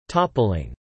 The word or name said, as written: toppling